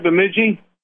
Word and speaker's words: Bemidji: Did I say that right, Bemidji? – Bemidji Woolen Mills.